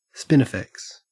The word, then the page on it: spinifex: Australian English